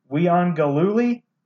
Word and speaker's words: Uiagalelei: DJ Uiagalelei is how you pronounce his last name.